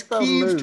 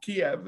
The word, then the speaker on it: Kiev: I noticed it's Kiev to rhyme with Steve, not Kiev as well.